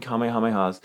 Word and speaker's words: Kamehameha: Along the stretch of road, originally built in 1845, warriors said to have perished at the hands of King Kamehameha's military not Kamehamehas. It's so hard not to say it like that. But King King Kamehameha's military. Kamehameha's, yes, thank you.